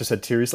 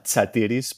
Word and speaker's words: Satiris: My last name uh, is pronounced Dr. Satiris, like satire. Uh, if you want to say the Greek word, it's uh, Satiris, but most people struggle with that ts in the uh, beginning part of the name.